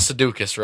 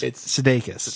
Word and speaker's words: Sudeikis: It's not Sudeikis, right? – No, it's Sudeikis.